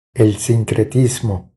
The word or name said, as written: El sincretismo